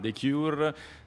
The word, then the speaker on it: The Cure: Lady Gaga, The Cure, l'ho detto bene, sì.